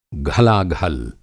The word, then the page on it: घलाघल: Hindi